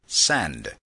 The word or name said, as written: send